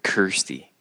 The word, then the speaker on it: Kirstie: And Christy Alley's hair being so big. – Yeah, her hair was a lot of Kirstie.